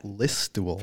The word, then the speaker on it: Listowel: Listowel.